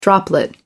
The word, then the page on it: droplet: American English